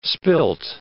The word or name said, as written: spilt